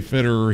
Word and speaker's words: Federer: Of the 14 times he played Federer, he won 10 times. – Federer. Doesn't sound like you're saying it correctly, but that's the way it's said.